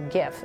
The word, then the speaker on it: GIF: uh, the jar takes a stand in a popular online event saying that the word GIF should be pronounced with a hard G, not a soft one. So GIF, yeah. The creator of GIF has previously said it should be pronounced with a soft G, like the peanut butter brand, right?